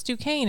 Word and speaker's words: Duquesne: Well, it's Duquesne. It rhymes with rain.